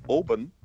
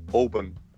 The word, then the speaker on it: Oban: He went, it's not Oban, it's Oban. And I was like, yeah, well, whatever, John. Yeah, Oban. He just said it the same both times.